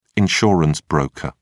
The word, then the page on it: insurance broker: British English